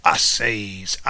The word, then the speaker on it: seis: I seis, I seis, I seis. Okay? For those who can't remember Foghorn Leghorn, you remember me saying I says, okay? Because that's how you pronounce it, says.